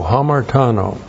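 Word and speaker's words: Hamartano (ἁμαρτάνω): And then we have hamartano. H-A-M-A-R-T-A-N, long O. Hamartano.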